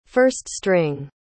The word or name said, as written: first-string